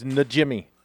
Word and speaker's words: Najimy: It's Najimy. – Najimy. – It's not Jimmy. It's Najimy.